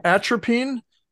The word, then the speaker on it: atropine: Atropine, scopolamine, and ephedrine.